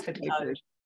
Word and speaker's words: Twyford: Is it Twyford or Twyford? I never even thought to ask you. – It is Twyford.